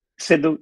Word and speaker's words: Sidhu: Sidhu, yes.